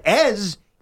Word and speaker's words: Eze: I was in a town called Ez. Eze. That's the right. – That's how you spell it. I was told it's actually pronounced as, like as France.